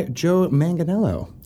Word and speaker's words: Manganiello: We are joined by Joe Manganiello.